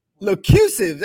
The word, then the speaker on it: Locusive: Locusive.